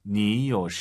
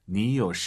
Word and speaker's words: 你有: 여러분 요는 올라가면 안 되죠. 뒤에가 이성이잖아요. 삼성 이성. 요 샨. 요 샨. 니요 샨. 니요 샨머 建议吗?